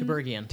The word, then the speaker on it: Tiberghien: The Other Thing, Emily Schwend, directed by Lucie Tiberghien. – Tiberghien.